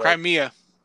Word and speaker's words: Crimea: So, Crimea. Almost like Crimea River, but not really.